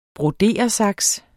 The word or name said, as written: broderesaks